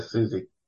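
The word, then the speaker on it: Siouxsie: But yeah, Susie.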